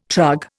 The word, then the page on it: trug: Indian